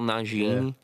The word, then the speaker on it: Nanjiani: Numail Kanjuini, or I don't know I'm pronouncing that wrong. Zach Kumail. – Woods. Kumail Nanjiani.